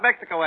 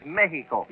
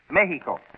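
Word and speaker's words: México: Oh, no, not Mexico, Eddie. The Spanish pronunciation is Mexico. – Yeah? – You make the X sound like an H, you see? Mexico.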